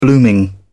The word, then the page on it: blooming: British English